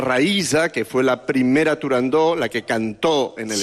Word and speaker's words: Turandot: Turandot. – No. – Turandó. – No. No se dice, no se debe pronunciar Turandot. – Ah, no, Turandó. – La ópera se titula Turandó.